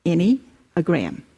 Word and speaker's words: Enneagram: Like Doug said, for the next 3 months, we are going to be speaking on the Enneagram, and it's pronounced A-N-Y, Enneagram.